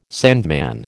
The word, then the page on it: sandman: American English